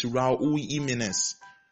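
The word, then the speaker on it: Jiménez: And then it's this other one, the Wolves striker, it's Raul Jimenez. Raul Jimenez. It's not Jimenez. He's Mexican, so the J is usually silent. It's Raul Jimenez.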